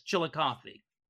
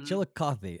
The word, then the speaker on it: Chillicothe: Chillicothe. I think I pronounced that right. I'm not sure. – Yes, Chillicothe. – Okay, Chillicothe.